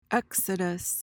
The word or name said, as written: exodus